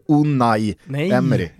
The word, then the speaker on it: Unai Emery: Du måste lära dig att han heter Unai Emery. – Det är olika det där, beroende på spanska uttal. I Galicien, södra Galicien, där säger man Unai.